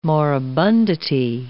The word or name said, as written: moribundity